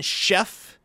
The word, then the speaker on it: Szefc: John Sheff. – Yeah, but it's spelled S-Z-E-F-C.